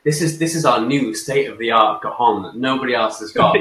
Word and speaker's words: cajon: It's a cajon. It's spelled C-A-J-O-N, with a silent J.